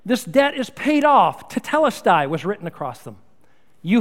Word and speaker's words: tetelestai: Now, this phrase, "it is finished," is from a Greek word, a single word, pronounced tetelestai.